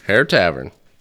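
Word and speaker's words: Herr Tavern: Hare Tavern. H-E-R-R.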